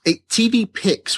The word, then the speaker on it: TVPYX: TV Picks, which is spelled TVPYX.